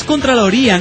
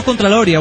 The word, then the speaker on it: contraloría: Pirata es contraloría, no contraloria. Bueno, ganso, no, yo lo digo como me place, sí. – Son muy mis contralorias. Y dice, bueno, y con acento en la I, bueno, me vale.